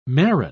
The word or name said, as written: marron